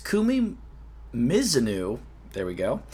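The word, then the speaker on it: Kumi Mizuno: Nate brings it to my attention that we've been saying Kumi Mizunu's name wrong for 5 years. We've been saying Mizunu for 5 years. It's Mizunu.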